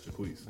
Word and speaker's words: Jacquees: His name is Jacquees. Is that how you say his name right? – Uh-huh. – Okay, Jacquees.